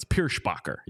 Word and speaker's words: Pierschbacher: He is tough name to pronounce. Pierschbacher. You got to get the shh in there.